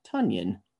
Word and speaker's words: Tonyan: Finally came out and said his last name is pronounced Tonyan, like Funyuns.